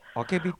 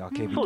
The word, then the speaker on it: 明日: の作品であけびっていうのは、まあ明日と書いてあけび、